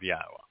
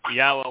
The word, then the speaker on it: Yahweh: The Y in Yahweh is pronounced exactly as we would pronounce the vowel consonant Y in English, like in the word yes.